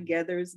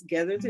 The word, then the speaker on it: Geathers: I am Giovanna Gethers. Gethers is spelled G-E-A-T-H-E-R-S. It's like feathers with a G.